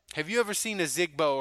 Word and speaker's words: Ozigbo: Isn't it Ozigbo? Ozigbo? – Ozigbo. So, yeah.